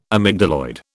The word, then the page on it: amygdaloid: British English